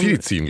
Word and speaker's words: Fidicin: Habe ich die ausgesprochen? – Fidicin, glaube ich. – Fidicin, Entschuldigung.